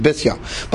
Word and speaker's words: Bisya: So when Basya, as I mentioned yesterday, proper pronunciation is Bisya, but when I'll mispronounce it like the rest of us do.